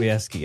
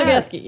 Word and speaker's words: Sobieski: How do you say her last name? – Sobieski, yes. – Sobieski, yeah.